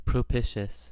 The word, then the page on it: propitious: American English